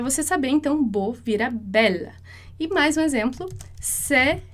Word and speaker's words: belle: Vai ser belle, porque de qualquer modo, o E final ali, ele não tem som.